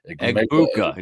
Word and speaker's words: Egbuka: Egbuka. It's not hard. Egbuka. It's just the way it's written. – Egbuka. Is it? I thought there was a G in there. – E-G. Yeah, Egbuka.